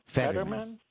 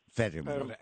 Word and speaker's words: Fetterman: Okay, Petterman. – Fetterman. Fetterman. F. F. – Okay. – It's the F word.